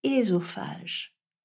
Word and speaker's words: œsophage: En français, j'ai toujours entendu le E dans l'eau du mot œsophage prononcer E, donc un E fermé. Eh bien, il faudrait prononcer ésophage.